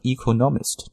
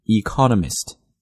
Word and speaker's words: economist: 那这个单词中国同学经常把它读成 economist, no, this is a wrong pronunciation because the stress isn't in the proper position, 啊，这个重音的位置并不对，所以呢经济学家听我读应该读成 economist, economist, 重音呢应该落在 co 上面， economist, right, 跟那个 economy 是一样的， economy, economist, economic, 所以在这里大家要注意一下。